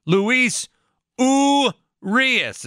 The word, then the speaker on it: Urias: But the Brewers, I thought the big story was going to be Luis Urias is how you say that name.